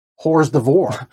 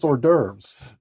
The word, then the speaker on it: hors d'oeuvres: That's not a band. It's pronounced hors d'oeuvres.